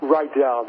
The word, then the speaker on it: Rydall: Rydall, there you go.